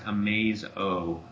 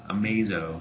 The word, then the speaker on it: Amazo: So they're saying it's pronounced Amazo. Amazo.